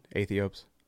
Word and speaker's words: Aethiops: That's how I pronounce it. Aethiops.